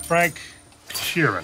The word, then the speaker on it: Frank Sheeran: Frank Sheeran. Am I saying that right? – Yeah, you said it right.